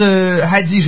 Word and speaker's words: hadiths: Les 40 hadiths, j'espère que je l'ai bien prononcé, Madame Delcam.